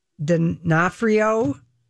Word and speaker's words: D'Onofrio: Vincent D'Onofrio.